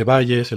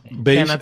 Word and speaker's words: Bayes: Pero en español se dice bayesiana, se dice Bayes, el teorema de Bayes. Bayes es la pronunciación original, sí.